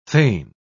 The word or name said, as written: fain